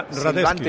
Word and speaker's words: Radetzky: De sí, pero tiene una C que es Radetzky, es Radetzky. – Sí, se pronuncia Radetzky.